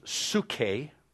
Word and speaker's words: suche: It is, life means suke, It's spelled like this, S-U-C-H-E.